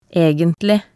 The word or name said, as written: egentlig